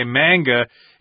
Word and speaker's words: manga: Indeed, and we've got the word manga here, M-A-N-G-A, which is from Japanese, but in English, oftentimes, you know, A-N words are an, like man, can, etc. So we say manga, even though in Japanese it's manga.